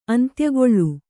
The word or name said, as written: ಅಂತ್ಯಕಾಲ